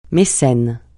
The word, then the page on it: mécène: francais